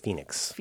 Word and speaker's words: Fenix: It's not pronounced Fenix. It's pronounced Phoenix. I said Fenix. F-E-N-I-X.